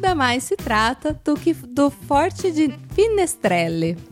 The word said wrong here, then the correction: Fenestrelle: Fenestrelle. – Fenestrelle, é. é. Forte de Fenestrelle.